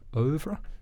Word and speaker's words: oeuvre: Yeah, so I've been rediscovering that oeuvre.